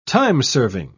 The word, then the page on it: timeserving: British English